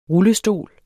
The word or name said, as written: rullestol